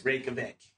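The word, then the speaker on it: Reykjavík: The Reykjavik? Is that how you pronounce it? – Reykjavik.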